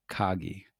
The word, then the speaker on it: KAGI: Do you say KAGI or KAGI? – KAGI. – KAGI. – And yeah, I'm Canadian, so our A's are typically hard.